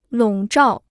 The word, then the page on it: 笼罩: lǒng zhào